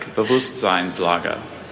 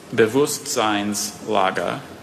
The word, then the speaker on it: Bewusstseinslage: Bewusstseinslage. – Can you please repeat both pronunciations? – Oh, you nerd! Let's do it. – They're very slight variants. So, Bewusstseinslage and Bewusstseinslage.